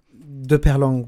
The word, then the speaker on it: Peyrelongue: Benoît, dis-moi ton nom de famille pour que je le prononce comme il faut. De Perlong. Bonjour, Daniel. De Perlong.